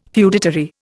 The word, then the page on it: feudatory: Indian